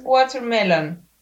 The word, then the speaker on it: Watermelon: O sea, no es watermelon, es watermelon. – Watermelon. Como voy a ir al water, no. – O sea, la, la T no se pronuncia. – Claro. – Y es como water. Water. Y la M igual muy sutil, como warmer and Sugar, hi.